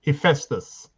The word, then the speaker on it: Hephaestus: or called Hesphasius, that's how you say it in the Greek way, and the god of fire. And he's an amazing god. He's not really talked about much. – Some people pronounce it Hephaestus. – Thank you. Okay, that's very much better.